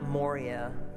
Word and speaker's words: Moriah: The Mount Moriah is how you pronounce it. Mount Moriah is how it often gets pronounced.